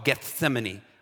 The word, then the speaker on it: Gethsemane: It starts on this mountain called Gethsemane. I don't have a lisp, but that's how you say it.